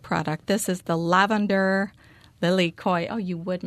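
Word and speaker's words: lilikoi: Lily koi.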